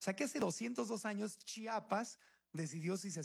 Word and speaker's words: Chiapas: Hoy hace 202 años, Chiapas, se pronuncia Chiapas, no Chapas, porque a los chiapanecos no les gusta que le digan Chapas.